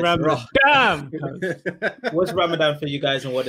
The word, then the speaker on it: Ramadan: Well, first of all, pronounce it. He said Ramadan. It's Ramadan.